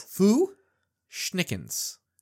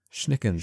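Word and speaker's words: Fu-Schnickens: How do you say this? Foo Schnickens? – Foo Schnickens. – Schnickens. – Schnickens. – Foo Schnickens. All right.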